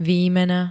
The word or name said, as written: výmena